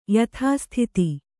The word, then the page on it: ಯಥಾಸ್ಥಿತ: yathāsthita